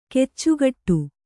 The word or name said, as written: ಕಚ್ಚೆಗಟ್ಟು